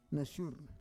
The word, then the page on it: nasyur: Roon